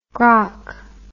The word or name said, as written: grock